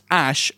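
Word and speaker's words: Æ: XAE, which is pronounced Ash, apparently.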